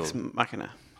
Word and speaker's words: Ex Machina: Is that my pronunciation? What pronunciation do you want to go for? – Yeah, cool. Ex Machina.